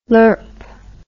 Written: lurp

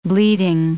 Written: bleeding

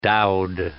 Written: dowed

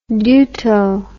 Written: deuto

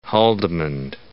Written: Haldimand

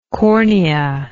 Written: corneal

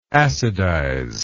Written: acidization

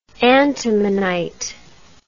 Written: antimonite

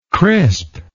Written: crispness

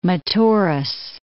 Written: Metaurus

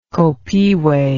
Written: copihue

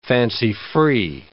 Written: fancy-free

